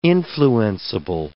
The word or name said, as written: influenceable